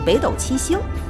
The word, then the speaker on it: 北斗七星: 夜茶小课堂，斗字除了第四声，其实还有第三声这个读音，比如北斗七星。